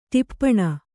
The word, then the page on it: ಟಿಪ್ಪಣ: ṭippaṇa